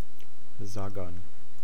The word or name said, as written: sagan